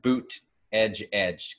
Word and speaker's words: Buttigieg: And I don't know if you've seen his campaign signs had boot edge edge because that's how you pronounce it. So it's boot edge edge, and he made it work.